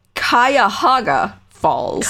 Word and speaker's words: Cuyahoga Falls: I know that I butchered Robert Burdella's hometown. I know this. I have it written down. Apparently, it is Cuyahoga Falls. – Cuyahoga Falls. I think Cuyahoga you said it right Falls.